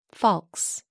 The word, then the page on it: falx: American English